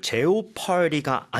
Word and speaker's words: jeopardy: 그리고 발음도 여러분들 jeopardy가 아니네요, 그렇죠? – Jeopardy. – Jeopardy. 그래서 jeopardy. O가 아예 발음이 안 됩니다.